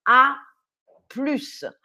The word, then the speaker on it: à plus: Ne pas oublier de bien prononcer le S à la fin. A plus.